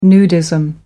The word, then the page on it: nudism: American English